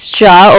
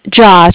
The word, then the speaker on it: Jia: Yeah, this is Ja over at the Bank of Cardiff. – One second. – Okay. – Sorry, what did you say your name was again? – Ja, J-I-A, with the Bank of Cardiff. – Cardiff? – Diff, D-I-F-F, Frank.